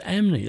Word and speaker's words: Ampney: but I found eventually it is pronounced as Amney. They don't say the P.